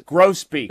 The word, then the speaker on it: grosbeak: This is grosbeak, I'm told it's pronounced.